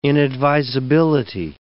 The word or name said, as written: inadvisability